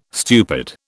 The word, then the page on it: stupid: British English